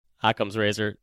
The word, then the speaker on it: Occam's razor: I thought that my explanation was Occam's razor. Yours is like really. Did I say that right? Is it Occam? Occam? – Occam's razor. That's how I've always pronounced it.